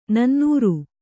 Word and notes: ನನ್ನೂರು: nannūru

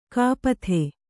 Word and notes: ಕಪಿತೆ: Kannada

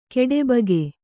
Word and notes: ಖಟಬಾಕಿ: kannada

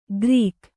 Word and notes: ಕರಿಗ: Kannada

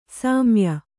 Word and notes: ಸ್ಮಯ: smaya

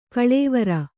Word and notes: ಕೊಳವೇರು: koḷavēru

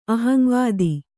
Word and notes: ಅಹಂವಾದ: aham vāda

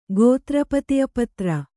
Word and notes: ಗೋತ್ರಪತಿಪತ್ರ: kannada

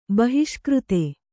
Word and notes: ಬಹಿಷ್ಕೃತೆ: kannada

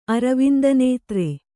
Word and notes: ಅರವಿಂದನೇತ್ರ: aravindanētra

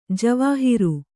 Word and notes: ಜವಾಹಿರು: javāhiru

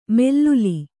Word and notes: ಮೆಲ್ಲುಲಿ: mellulii

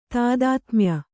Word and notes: ತಾದಾತ್ಮ್ಯ: kannada

